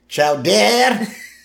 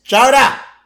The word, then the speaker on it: chowder: Xiaodie. Xiaodie. It's chowder. Say it right.